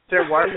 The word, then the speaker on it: terroir: What does, am I saying that correctly, Terror? – Yes, you are really, really close on that. Depends on if you're French or not. – No. – Terroir means soil.